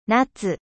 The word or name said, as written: ete